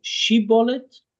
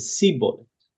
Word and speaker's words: shibboleth: And there's this plant called shibboleth, and the two tribes pronounced the name of this plant slightly differently. One of them said shibboleth, and one of them said sibboleth.